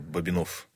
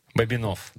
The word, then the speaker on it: Бобинов: И так же, как Ерофей Хабаров, ударение на последний слог. Да, то есть ученые пермские опрашивали жителей вот сел там Верхьяево, где он упокоился, и окрестностей, и у них именно такое произношение, это вот Бобинов.